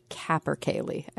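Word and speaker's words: capercaillie: Just don't write in to tell Jay that it's actually pronounced Capper Cayeli.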